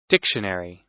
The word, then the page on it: dictionary: American English